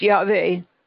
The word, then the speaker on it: Yahweh: And it's pronounced Yahweh.